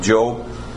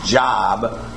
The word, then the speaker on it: Job: "There was a man from the land of Uz whose name was Job." If we'd have been trained right in phonics, that's how we'd pronounce it again. But most of us have called him Job so long that we'll stick with that.